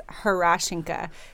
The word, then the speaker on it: Herashchanka: Yes, Herashchanka. So everybody thinks it's Herashchanka, but it's actually Hurrah, like you're happy, Hurrah, Shin, like on your leg, Ka, Herashchanka.